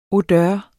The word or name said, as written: odør